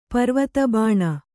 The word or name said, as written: ಪರ್ವತಬಾಣ